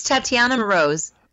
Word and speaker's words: Tatiana Moroz: It's Tatiana Moroz. – Okay. – It's less sad sounding. Sometimes people kind of like Moroz, and yeah. I'm like, not quite, but sort of.